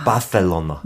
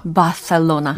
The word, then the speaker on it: Barcelona: But 그 C 소리가 약간 TH처럼 아, Barcelona. Barcelona.